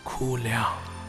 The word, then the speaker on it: Kuliang: Guling is the Mandarin pronunciation. In our local dialect, it's called Kuliang.